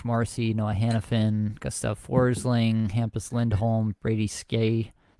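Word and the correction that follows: Skjei: How do you say that name? – Brady Shea.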